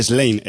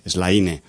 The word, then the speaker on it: Slaine: Slane, se escribe S-L-A-I-N-E, Slane.